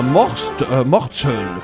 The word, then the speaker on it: Mortsel: qui m'a interpellé, parce qu'en Belgique, dans le sud d'Anvers, qui est une ville en Belgique, à Mortsel, c'est comme ça que ça se prononce.